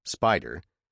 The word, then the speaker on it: SPDR: If this afternoon, for example, I were to buy 100 shares of the ETF called the SPDR, pronounced SPIDER,